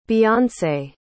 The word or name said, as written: Beyonce